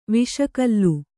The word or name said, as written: ವಿಷಕಲ್ಲು